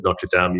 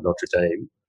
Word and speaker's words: Notre Dame: So, I'm interested in your undergraduate degree, because you did that at we pronounce it slightly differently here in Australia. We call it Notre Dame. You call it Notre Dame.